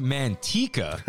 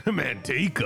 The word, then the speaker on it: Manteca: Emiliano writes in, the city of Manteca is actually pronounced Manteca. – Manteca.